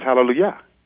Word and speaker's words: Hallelujah: I said, yeah, but there's no J in the Hebrew language. That's why you You may spell it like that, but it's Hallelujah.